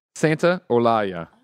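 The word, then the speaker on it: Santaolalla: Santolalla. Santolalla. – Santolalla. – Here spelled phonetically. Santa Olaya. – Oh, Santa Olaya.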